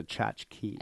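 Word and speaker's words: tchotchke: and culty tchotskis. Did I say that right? We don't say the word tchotskis here. – I thought it was a tchotchki.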